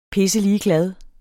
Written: pisseligeglad

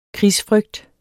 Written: krigsfrygt

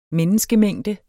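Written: MENNESKEMÆNGDER

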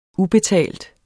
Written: ubetalt

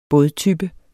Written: bådtype

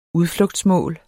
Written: udflugtsmål

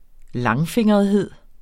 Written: langfingrethed

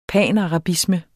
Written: panarabisme

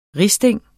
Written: risting

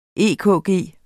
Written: EKG

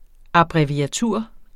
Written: abbreviatur

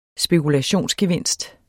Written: spekulationsgevinst

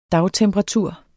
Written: dagtemperatur

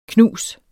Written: knus

